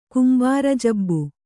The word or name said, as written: ಕುಂಬಾರಜಬ್ಬು